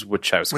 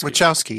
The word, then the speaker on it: Wachowski: As the Wachowskis wanted. – Which is really how I watched it. – I also want to say, some people say Wachowski, but I looked online and it is Wachowski. – Wachowski, yeah.